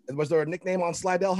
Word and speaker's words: Slidell: Did I say that right, Coach? – That's right, Slidell High.